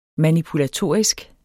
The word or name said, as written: manipulatorisk